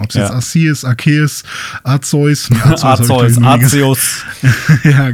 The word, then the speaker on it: Arceus: Aber ähm, ihr könnt sicher sein, es heißt Arceus im Englischen und Arceus im Deutschen. So wird es ausgesprochen.